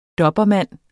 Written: dobermann

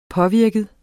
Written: påvirket

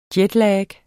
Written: jetlag

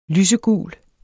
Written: lysegul

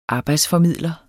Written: arbejdsformidler

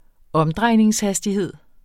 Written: omdrejningshastighed

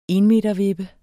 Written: ENMETERVIPPE

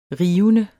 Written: rivende

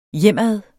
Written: hjemad

